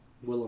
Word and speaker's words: Willem: And you mispronounced his name, by the way. – I'll admit it. – It's Willem. – Willem Dafoe. – It's Willem Dafoe.